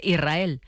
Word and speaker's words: Israel: Y la S con la R es difícil de pronunciar. La gente dice Israel, es verdad. – Que suene la S.